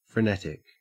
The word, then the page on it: frenetic: Australian English